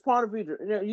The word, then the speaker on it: Ponte Vedra: No, it's Ponte Vedra. You said it right. Ponte Vedra.